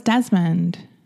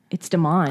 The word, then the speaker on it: Demond: I thought it was Desmond. – It's Demond. – Oh, it's Demond.